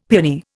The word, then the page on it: piani: Indian